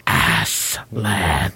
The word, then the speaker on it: Aslan: It's pronounced Aslan.